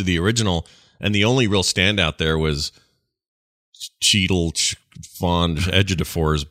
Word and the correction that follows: Chiwetel Ejiofor: I can't say his name right. It's 12 Years a Slave. – Chiwetel Ejiofor.